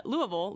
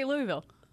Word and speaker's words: Louisville: You look at someone like Haley Van Lith, though, leaving Louisville this week. Actually, Jason Anderson and I spent, like I said, a lot of time together. He used to be in Louisville.